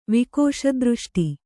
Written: ವಿಕೋಶದೃಷ್ಟಿ

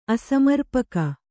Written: ಅಸಮರ್ಪಕ

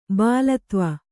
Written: ಬಾಳತ್ವ

